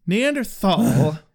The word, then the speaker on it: Neanderthal: Neanderthal is a perfectly legitimate pronunciation. Just because it was Germanic in origins and is generally honored by being pronounced Neanderthal does not mean that the Americanized pronunciation is not legitimate, pronouncing it with our Americanized TH, thal.